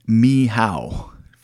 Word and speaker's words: Michał: But let me jump back really quick, and this is just going off of memory, but Father, his name looks like Father Michael. He's a Polish priest, but it's actually pronounced Michał, Father Michał.